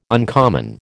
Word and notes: uncommon: American English

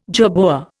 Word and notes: jerboa: Indian

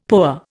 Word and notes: POA: Indian

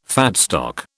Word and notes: fat stock: British English

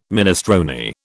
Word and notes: minestrone: British English

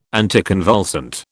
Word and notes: anticonvulsant: British English

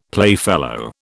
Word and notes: playfellow: British English

